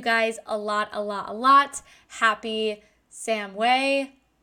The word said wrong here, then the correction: Samhain: Oh, God, I feel so bad. I always say it wrong. Okay, so wait. – Samhain. – Samhain. Great. Did you guys hear that? I had to truly go on Google and ask how to pronounce it.